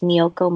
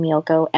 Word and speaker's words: Miyouko: It's just Miyoko Moto. My name is spelled weird. It's with a U on, between Miyoko, MIYOUKO and Moto.